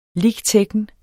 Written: lic.techn.

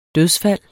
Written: dødsfald

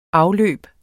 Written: afløb

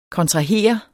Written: kontrahere

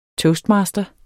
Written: TOASTMASTERE

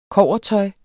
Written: kobbertøj

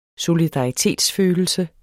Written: solidaritetsfølelse

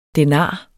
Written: denar

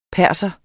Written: Persere

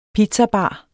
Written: pizzabar

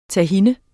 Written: tahin

